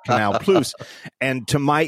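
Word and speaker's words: Canal+: And in French, apparently, it's pronounced Canal Plus. And I wrote as a little sort of joke that because it's French, it's pronounced plus, P-L-O-O-C-E, as my phonetic spelling for English people. And all these French readers are telling me that's not how it's pronounced at all.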